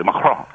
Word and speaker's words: Macron: But anyway, Macron, all right, let's say it properly, Macron, he basically playing to his crew.